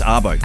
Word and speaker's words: Abo: Now, you might have seen yesterday that Sarah Abdo has resigned now from the Today Show. – Oh, Sarah Abo, yes. – Is that how you say it? – Yeah, I think it's Abo.